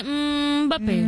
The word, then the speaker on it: Mbappé: Se dice Mbappé.